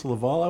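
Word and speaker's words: Leavell: So his last name is pronounced Laval. I always thought that Level. Was Level. – Yeah, well, it's written Level. – Because they pronounce it Laval.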